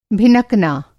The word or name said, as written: भिनकना